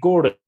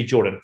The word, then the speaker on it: Geordan: So they changed it to Geordan, but they kept the start of G-E-O-R and just put D-A-N at the end. So everybody my whole life has always got my name wrong because they see G-O-R and they say Geordan, but it's actually Geordan.